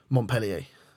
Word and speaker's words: Montpellier: Montpellier.